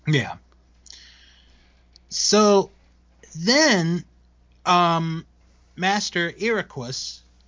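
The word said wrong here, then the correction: Eraqus: Did I say that right? – Almost. Ericus. – Ericus. Okay.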